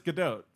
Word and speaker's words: Gadot: I was wondering if you're going to pronounce the T. – Supposedly it's Gadot.